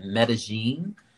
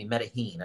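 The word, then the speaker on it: Medellín: I was on a trip with a couple of friends and we went to Colombia and we were staying in Medellin. Okay. Or Medellin. Excuse me, Medellin, I should say. Mm-hmm. That's how it's pronounced.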